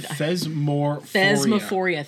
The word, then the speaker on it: Thesmophoria: Sometimes Thesmorphia was included. – Thesmophoria. – Thank you.